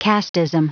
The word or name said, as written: casteism